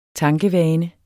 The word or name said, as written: tankevane